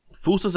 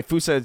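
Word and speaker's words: fossa: Yeah, wow, that's, so by the way, fusa is not how you think it's spelled. It's F-O-S-S-A on Google.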